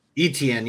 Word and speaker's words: Etienne: Etienne, yeah, there we go.